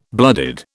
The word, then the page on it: blooded: British English